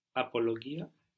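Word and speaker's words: απολογία: Mia apologia. – Mia apologia. Pronounced like a Y.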